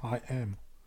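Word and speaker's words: HAIM: Wo wir gerade bei den Gastauftritten sind, wir haben noch zwei weitere Gastauftritte auf dem Album oder Heim. Features. Einmal Heim bei Nobody No Crime und dann nochmal Bon Iver bei Evermore. – Bitte, wirklich? – Kollege. Aussprache ist I am.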